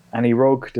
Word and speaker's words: Anirog: Anirog, does that sound like the right pronunciation? Anirog Software. – I believe that's correct.